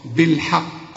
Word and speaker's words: بالحق: هنا بقى ما نقولش بالحق، فاكر لما قلت لك تذكر وله الحاء الحمد، شوف وله الحمد، شوف الحاء هنا الحاء، هنا جيت وقلت ايه بالحاء بالحق، ما هي الحاء بتاعت الحمد هي نفس الحاء بتاعت الحق، لكن بالحق فيها حرف من الحروف المفخمة علشان كده معرفتش تنطق الكلمة صحيحة. لكن قل لي بالحاء الأول، بالحاء زي ما أقولك إزاي الحال، واخد بالك، فقل لي بالحاء وبعدين فخم لي القاف بالحق.